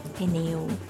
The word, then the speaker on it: pneu: But in Portuguese, it's both vowels are pronounced the same way, like pneu, the same amount of time for both.